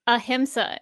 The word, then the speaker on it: ahimsa: Ahimsa is how they pronounce it.